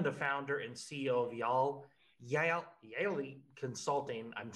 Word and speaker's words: Yael: Yaeli. – Y-A-E-L Consulting.